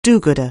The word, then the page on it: do-gooder: anglais